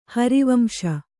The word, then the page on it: ಹರಿವಂಶ: hari vamśa